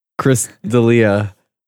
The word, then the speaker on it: Chris D'Elia: Someone made a good point that this whole Christiella shit. Uh, and did I say his name wrong again? Chris Thiella. Chris D'Elia.